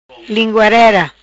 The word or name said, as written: Linguarera